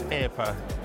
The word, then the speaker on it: AirPA: Am I saying that right, AirPA? I've never really asked you. AirPA? Well, I know, and people do ask. It's AirPA. Thank you very much.